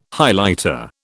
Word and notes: Highlighter: British English

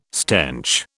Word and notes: stench: British English

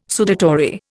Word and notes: sudatory: Indian

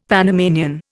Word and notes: Panamanian: Indian

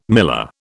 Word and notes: miller: British English

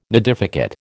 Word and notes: nidificate: American English

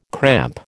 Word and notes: cramp: American English

